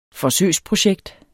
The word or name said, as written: forsøgsprojekt